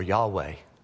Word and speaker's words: Yahweh: You've heard it before, Yahweh. Four consonants, YHWH, and you insert the vowels to be able to pronounce it.